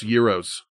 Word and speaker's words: gyros: Gyros. – Gyro. – They're pronounced euros.